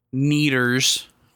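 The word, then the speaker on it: Kneaders: There was a little sandwich shop across the street, Neaters, with a K, silent K at the beginning.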